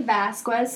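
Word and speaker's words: Vazquez: My name is Desiree Vasquez, spelled with a Z, not an S.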